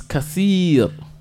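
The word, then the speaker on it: kasir (كثير): The word for much is kasir. Kasir. Kaf. Sa. Yao. Elongation. And ro. Kasir.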